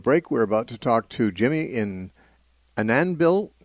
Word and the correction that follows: Annandale: I don't really know if that's right, Minnesota. Is that how you say it? – Well, thank you, Steve. It's Annandale, Minnesota. – Annandale, okay.